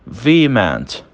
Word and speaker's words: vehement: Segunda palavra, nós temos vehement. Vehement. Vehement também não tem o H sendo pronunciado, ele não tem participação no som.